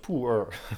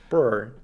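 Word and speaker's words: pu'er: en dat spreek je zo uit, poer? Je spreekt dat uit als poer. Purr.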